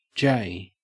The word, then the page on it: jay: Australian English